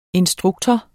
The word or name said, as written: instruktor